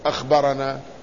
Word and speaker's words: أخبرنا: وكذلك عندما يكتبون أخبرنا فيحذفون الهمزة، يحذفون الخاء والراء والباء، ويكتفون بالهمزة والنون والألف كأنها أنا، لكن لا تنطق أنا وإنما تنطق أخبرنا.